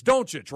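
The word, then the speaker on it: Dončić: I don't mean to pump fake you, because it's Doncic, right? It's the correct pronunciation of his name.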